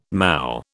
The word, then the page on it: moue: British English